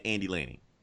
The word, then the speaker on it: Andy Lanning: Dan Abnett and Annie Lane. Am I saying that right? Fucking it up, Anna. – Dan Abnett? – Dan Abnett and Andy Lanning.